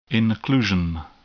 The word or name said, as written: inclusion